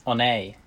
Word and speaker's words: Oney: Is it Oney? – Oney.